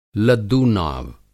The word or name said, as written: लद्दूनाव